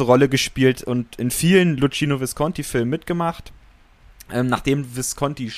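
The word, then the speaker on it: Luchino: der Ende der 60er von ähm, Lucino Visconti oder Lucino Visconti, er selbst hat, glaube ich, mal gesagt, dass, äh, dass, dass Lucchino Visconti Lucino Visconti ausgesprochen wird, ist auch egal.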